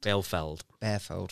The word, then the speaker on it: Bielefeld: Bielefeld, thank you.